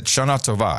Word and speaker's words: Shana Tova: Shana Tova, I think is it Shana Tova? I think is the way you pronounce it. That's right.